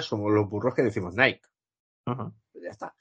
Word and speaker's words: Nike: Yo creo que, de hecho, se debe pronunciar Nike, ¿no? Eh, creo que sí, creo que sí. Pero aquí en España, como toda la vida ha sido Nike, en la, en la película dicen Nike.